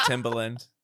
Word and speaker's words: Timbaland: He is now this incredible, as far as I'm concerned, the Timbaland of, did I say that right? – That's right. Yeah. Yeah. Timbaland.